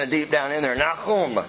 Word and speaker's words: Nahum: It is pronounced Nahum. Can you say that with me? Nahum. It's not Nahum. Nahum. That is so Western Americanized.